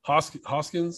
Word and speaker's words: Hoskins: Um, no, look, I like I like some of their team, and then uh, Hos- I don't know how to say his name, but that that kid Hoskins. Is that how you say his name? Yes.